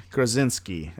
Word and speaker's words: Krasinski: So John Krasinski. I said that right? – Yeah.